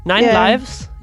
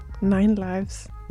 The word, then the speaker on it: IXLIVZ: Jeg ved ikke engang, hvordan man udtaler bandnavnet. IXLIVZ. – Eller ni. Nine Lives, jo. – Nå, for fanden. Nine Lives.